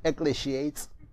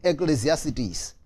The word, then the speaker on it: Ecclesiastes: Okay, some of you call it Ecclesiates. Okay, I'm pronouncing it the Miles Monroe way. Ecclesiastes.